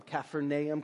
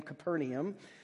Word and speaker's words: Capernaum: Capernaum, Capernaum.